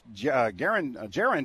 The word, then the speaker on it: Gerren: Substitution for the Hillcats, Jaren Jackson checks into the ball game. G-E-R-R-E-N, but pronounces like a J.